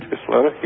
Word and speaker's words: Isleta: it's Isleta, is that, does that sound right? – Isleta, yeah.